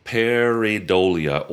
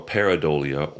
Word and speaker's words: pareidolia: And the word I should have used is called pareidolia or paradolia, or it's pronounced sometimes pareidolia. – Mm-hmm. – It's spelled P-A-R-E, pair. – Mm-hmm. – I-D-O-L, like idol. – Mm-hmm. – I-A. Pareidolia.